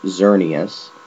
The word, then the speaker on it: Xerneas: It's pronounced Xerneas.